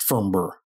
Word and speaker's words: Farnborough: And we got some mail and we're told that the correct pronunciation from someone who's from the area, it's pronounced, and this was written to us, so I'm going to probably get it wrong again, but it's pronounced Farnborough.